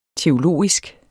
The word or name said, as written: teologisk